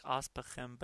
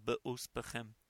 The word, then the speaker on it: be'ospechem: It looks like aspechem, but it is pronounced be'ospechem.